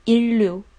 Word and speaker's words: 인류: 这里的发音，人类 인 류 인 류 의 진 화 를 목 격 한 다。